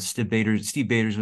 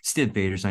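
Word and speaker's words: Stiv Bators: And Steve Bader's was around. Stiv Bader's, I know is how it's more often pronounced.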